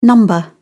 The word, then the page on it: number: British English